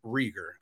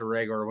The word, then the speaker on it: Reagor: And and let me tell you, someone who saw plenty of, uh, well, Reagor, I guess um, I, I, I, always pronounce a Rager when he was at TCU. It's hard to break.